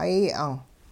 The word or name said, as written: hay 'ang'